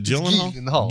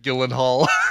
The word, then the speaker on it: Gyllenhaal: Is it Gyllenhaal? Gyllenhaal. Yeah. It's hard G. It's like a GIF.